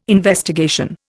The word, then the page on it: investigation: Indian